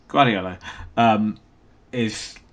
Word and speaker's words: Guardiola: Guardiola is...